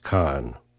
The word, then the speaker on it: Kahan: So Noah Kahan, and it's K-A-H-A-N. But it's pronounced Khan.